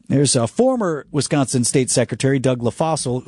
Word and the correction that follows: LaFollette: LaFollette. – Did I say it wrong again? – LaFollette. – Are you sure? – I am positive.